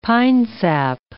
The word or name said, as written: pinesap